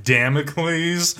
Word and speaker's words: Damocles: I believe it's pronounced Damocles. – Okay.